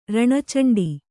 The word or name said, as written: ರಣಚಂಡಿ